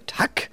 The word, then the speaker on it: Tack: Ah ja, ja, Tack. Schon. Also, so betont. Tack. So, nach dem Wunder. Habe ich das jetzt richtig gesagt, Tack. Herr Schwede? – Ja, du hast richtig gesagt.